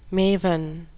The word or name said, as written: maven